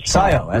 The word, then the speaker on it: Scio: Mark in CO, Oregon, am I saying that right? – CIO. – SCIO. Okay.